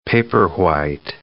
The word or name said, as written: paperwhite